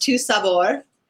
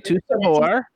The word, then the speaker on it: Tu Sabor: So I like your pronunciation because I'm a big Star Wars fan, but it's actually pronounced Tu Sabor. – Okay, Tu Sabor.